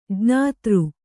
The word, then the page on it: ಜ್ಞಾತೃ: jñātř